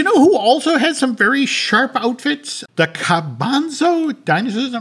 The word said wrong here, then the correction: Cabazon: Am I pronouncing that right? Cabanzo? – Cabazon.